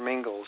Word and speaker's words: Mingils: And again, my name is Peter Mingils. Sounds like Pringles, but it's with an M-I-N-G-I-L-S for those people that want to learn how to spell it.